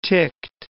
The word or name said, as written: ticked